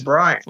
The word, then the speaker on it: Brian: So like, what they really my name is Brian.